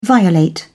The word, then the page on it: violate: British English